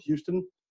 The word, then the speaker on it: Hueston: You can also reach me via Instagram at Coach Phil Houston. Spell the last name right, H-U-E-S-T-O-N. Also at through Twitter, at Phil Houston. Again, spell the last name right, H-U-E-S-T-O-N.